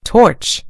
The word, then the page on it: torch: British English